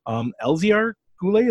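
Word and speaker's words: Elzéar Goulet: Elziar Goulet. Is that right? Did I pronounce that right? – Yeah.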